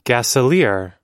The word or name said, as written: gasolier